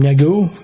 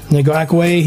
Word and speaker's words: Ngakoue: And then there's this Yannick, I can't even pronounce this guy's last name, Negu? – Ngakoue.